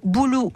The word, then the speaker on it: Bouloux: Alors, vous tapez sur un moteur de recherche Radio Boulou Junior. Boulou, ça s'écrit comment, Aïna B-O-U-L-O-U. – X.